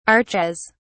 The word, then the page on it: archaise: British English